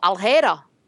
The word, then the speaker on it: alheira: No, aljera.